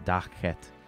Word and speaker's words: Lakhet: From tribe Dakhet, it's spelled Lakhet, but it's pronounced Dakhet, is Reinkike Bu, or Hu.